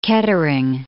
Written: Kettering

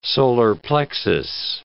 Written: solar plexus

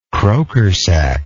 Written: crokersack

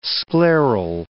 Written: scleral

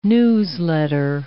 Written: newsletter

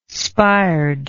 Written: spired

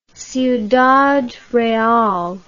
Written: ciudad real

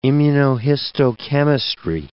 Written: immunohistochemistry

